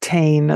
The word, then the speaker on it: tain: That's T-A-I-N, not tain, as most people pronounce it. It's tain, okay?